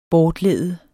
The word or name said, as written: bortlede